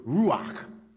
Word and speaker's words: ruach: And in Hebrew, the word spirit was the Hebrew word ruach. You got to say it like that, like something's caught in the back of your throat. That's how you say it in Hebrew. Ruach.